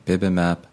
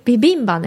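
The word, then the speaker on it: bibimbap: Furthermore, they have also added foreign words like bibimap, arancini. – Bibimba, ne? Bibimap じゃないよ。あの、韓国のほら、ビビンバってあるじゃん。Oh, bibimba. – そう。 – なんだ。How do you say it in English? – Bibimba. – Bibimba? – Yeah.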